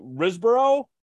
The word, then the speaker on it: Riseborough: Next, we have the controversial nomination of Andrea, I'm going to mispronounce this, Riseborough.